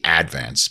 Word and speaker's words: Advance: or as we said in Staten Island, the Advance, putting the accent on the first syllable.